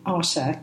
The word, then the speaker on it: Artá: We live in the north of the island in a town called Arta. Am I saying that correctly? – Arta.